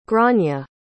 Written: gráinne

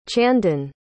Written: chandan

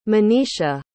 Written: monisha

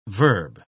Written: verb

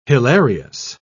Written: hilarious

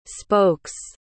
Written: spokes

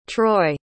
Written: troi

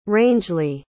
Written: Rangeley

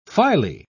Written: phyle

